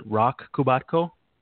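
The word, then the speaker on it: Roch Kubatko: Is it Rock Kubatko? – Yeah, you got it right there.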